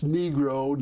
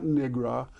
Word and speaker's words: Negro: Coleman Young went nuts against that and, and, and said that's you know you, that's not how you pronounce it. Um, it's Negro, not Nigra um,